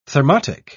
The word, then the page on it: thermotic: British English